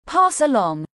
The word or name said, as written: Pass along